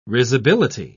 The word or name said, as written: risibility